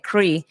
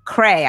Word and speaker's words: Cray: I was pronouncing it Cree. And he was like, he was like, you've been to Atlanta with us. How could, how dare you? It's Cray.